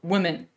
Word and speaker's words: Womxn: And you were supposed to pronounce that uh, women even though you had an X in place of the E. You were supposed to still pronounce it women.